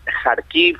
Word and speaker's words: Kharkiv: Primero, definitivamente Rusia está haciendo avances, y estos avances están en la zona noreste, está amenazando la zona de Kharkiv, Kharkov, como decías tú, uh-huh. de distinta forma se puede pronunciar.